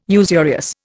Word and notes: usurious: Indian

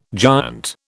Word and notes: giant: British English

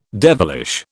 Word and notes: devilish: British English

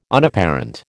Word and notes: Unapparent: American English

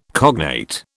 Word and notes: cognate: British English

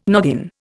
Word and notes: noggin: Indian

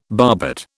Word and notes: barbet: British English

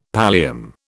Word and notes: pallium: British English

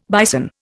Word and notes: bison: Indian

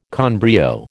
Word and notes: con brio: American English

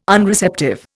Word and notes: Unreceptive: Indian

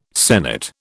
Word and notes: sennit: British English

